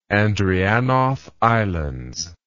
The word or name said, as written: Andreanof Islands